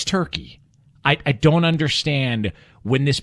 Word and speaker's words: Turkey: I'm not going to pronounce it Turkey. It's Turkey. I'm sorry.